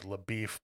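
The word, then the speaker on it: LaBoeuf: He's a Texas Ranger named LaBeef, but it's spelled LaBouf.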